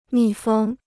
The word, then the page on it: 密封: mì fēng